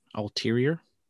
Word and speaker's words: ulterior: Is this just plain old heel Adam Cole doing what he does best, or does he have some other alterative motive? Am I saying that right? – Ulterior? – Yeah. – You said ulterior. Alteriative? Alteriative? – Ulterior. Ulterior, sorry. Sorry, I'm this many years old.